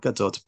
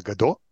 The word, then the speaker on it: Gadot: People pronounce it like it's French, Gadot. Like we are not waiting for Gal Gadot. We uh, her name is Gal Gadot.